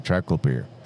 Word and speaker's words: triclopyr: I say triclopyr, but it's actually triclopyr.